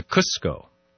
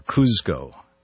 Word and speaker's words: Cusco: He garroted the emperor and his aides, buried their bodies, and began the long march to Cusco, or Cuzco, depending on how you pronounce it, the mountaintop capital of the Incan Empire, of what was then known as Tuantinsuyo.